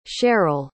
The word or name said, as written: Sheryl